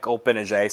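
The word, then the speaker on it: Gbinije: They are led by former Duke player Mike Olpinaje. Silent G will be back in Cameron.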